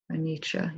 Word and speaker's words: Anicca: So Anicca is inconstancy, impermanence? – Yeah. – Okay. – Anicca, yeah. – Anicca. How do you spell that? – A-N-I-C-C-A.